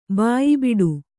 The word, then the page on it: ಬಾಯಿಬಿಡು: bāyi biḍu